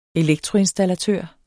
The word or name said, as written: elektroinstallatør